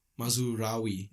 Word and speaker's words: Mazraoui: Mazraoui.